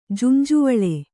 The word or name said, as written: ಜುಂಜುವಳೆ